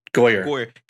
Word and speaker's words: Goyer: Goyer.